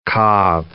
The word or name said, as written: Cobb salad